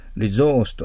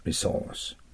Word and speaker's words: resource: 英国読み、例えば、ここではエマさんは、リゾースと S を Z のような発音で読んでるかもしれません。リゾース。両方正しいです。リゾース、リソース。